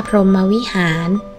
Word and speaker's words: พรหมวิหาร: พรหมวิหาร